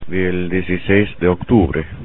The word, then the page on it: del dieciséis de octubre: spanish